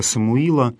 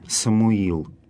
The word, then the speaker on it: Самуил: Кстати, имя последнего израильского судьи, пророка Самуила, повествование о котором мы находим в первой книге царств, имя это в церковнославянских текстах встречается с разным ударением «Самуил» или «Самуил». Хотя по-русски обычно говорят «Самуил».